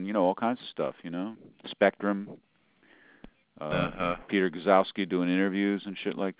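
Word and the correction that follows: Gzowski: The G is not pronounced. – Zowski.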